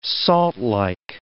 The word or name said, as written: saltlike